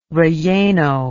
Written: relleno

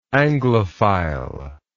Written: anglophilism

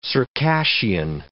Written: Circassian

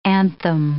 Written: anthem

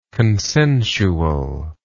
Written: consensually

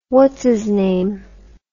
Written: whatsisname

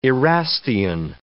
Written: Erastian

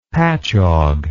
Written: patchogue

